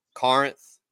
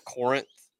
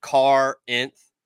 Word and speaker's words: Corinth: make sure they know that it's pronounced Corinth, not Corinth. the locals will tell you real quick. It's like Car-inth.